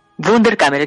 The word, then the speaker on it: Wunderkammer: Es la eh, editora de la editorial, eh, espero pronunciarlo correctamente, Bunderkammer, ¿no? – Wunderkammer, exactamente.